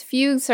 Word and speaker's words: fugue: Next word is fugue. – Correct. – Yes, so correct. It's spelled here as F-U-G-U-E.